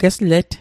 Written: geslet

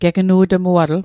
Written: gegnu'tmuatl

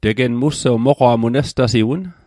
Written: moqwa'

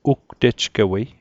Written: ugtejgewei

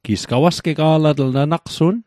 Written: gawasgega'latl

